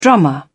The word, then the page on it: drummer: British English